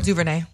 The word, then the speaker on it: DuVernay: DuVernay.